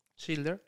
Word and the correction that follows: Schindler: Schindler. – Schindler.